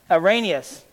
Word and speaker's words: Arius: I'm sorry, Arius. Arius is how you pronounce him, and he was quite the fellow.